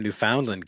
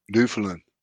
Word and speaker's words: Newfoundland: Howie says, watching from Newfoundland, Canada. – Newfoundland. – Yes. – Or Newfoundland. – Accent on the wrong syllable.